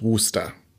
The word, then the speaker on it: Worcester: Also man kann auch Wustersoße sagen, denn äh, ähm, hergestellt wurde die Soße in der Stadt Wuster.